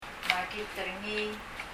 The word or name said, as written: makit er ngii